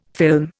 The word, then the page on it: film: Indian